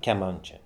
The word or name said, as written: kiamancha